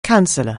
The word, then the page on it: counsellor: anglais